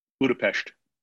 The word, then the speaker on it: Budapest: Is it Budapest or Budapest? – Budapest. – It's both. Mix them in there. – It's a combination. Pesh. Man, I've been pronouncing that wrong my whole life.